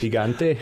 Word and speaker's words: gigante: And it's a big mountain, so they call it, well, it's gigante, but it's pronounced however you pronounce it, giant in Spanish. – Gigante? – Gigante, yes.